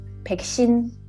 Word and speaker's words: 백신: 왜, 왜, 왜 백신이냐고. 저도 왜 왁친이야? – 라고 생각했어요. – 진짜 너무 다르네요, 생각보다. 그쵸? 음, 이게 어려워요. 네. 응, 음, 다시 한번 발음. – 아 이거요? 네. – 음. 백신. – 백신. 백신.